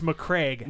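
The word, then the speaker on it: Macragge: had one of my favorite ones that was, I'm probably going to butcher this, the MacRaggies Cuddly Smurfs. – Yeah, it's McCraig.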